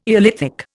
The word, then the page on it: eolithic: Indian